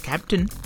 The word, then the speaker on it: Keptn: Keptn, pronounced as Keptn, is a control plane for DevOps automation of cloud native applications.